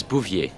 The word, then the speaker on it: Bouvier: The name's Bouvier. – Could you spell that, please? – B-O-U-V-I-E-R.